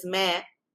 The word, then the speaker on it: Mare: So in the second film that you probably seen, which is Mare, like pronounced like the bleating of a goat.